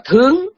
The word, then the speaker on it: thướng: Mà ở cái nghĩa động từ thì họ đọc là thướng, thướng là đi lên, mà nghĩa trạng từ là ở trên. Một chữ đó thôi, nhưng mà khi mà nó là trạng từ mình đọc là thượng, mà khi nó là động từ mình đọc là thướng.